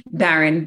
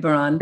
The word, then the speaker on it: Baran: Baron Baron.